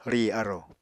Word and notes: rì arò: Apatani

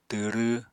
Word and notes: twrw: apatani

